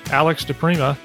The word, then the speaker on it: DiPrima: And today, I'm really looking forward to introducing you to our new friend, Alex DiPrima.